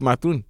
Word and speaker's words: Mathurin: Benedict Mathurin.